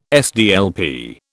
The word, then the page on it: SDLP: British English